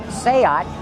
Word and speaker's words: Seat: If you look at it, looks like the name of it is Seat, but in Spanish, it's pronounced Seat, and that's because it's a Spanish company.